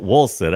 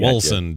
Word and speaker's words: Wolcen: It's spelled Wilson. I got Wilson, W-O-L-C-E-N.